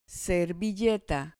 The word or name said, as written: servilleta